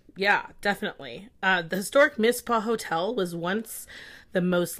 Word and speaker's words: Mizpah: So there's a hotel in Tonopah, Nevada called the Mizpah. – Right, and that's spelled M-I-Z-P-A-H.